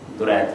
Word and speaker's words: درید: اس میں باقی ناموں کے تلفظ میں مسئلہ نہیں ہوگا, صرف یہ درید نہیں ہے, درید ہے.